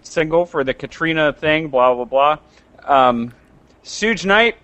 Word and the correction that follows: Suge Knight: How do you say that? – Suge Knight. – Damn it. I always forget that.